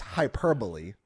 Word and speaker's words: hyperbole: It's hyperbolic, but it's hyperbole.